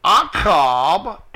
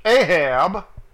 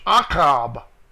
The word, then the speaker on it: Achab: So slept Omri with his ancestors and was buried in Shomeron, and Achab, 2:5-6, better known in King James as Ahab, his son reigned in his place. They spelled it wrong and pronounced it wrong in the King James. It's Achab. A-C-H-A-B.